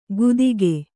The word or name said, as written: ಗುದಿಕೆ